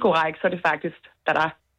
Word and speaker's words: Zara: Hvorfor ved du, hvordan man udtaler navnet på den tøjkæde, som staves Z-A-R-A? – Fordi jeg har spansk i gymnasiet, og der lærte vi, at Z på spansk udtales til H, som egentlig lyder mest som et D, og R udtales faktisk også lidt som et D.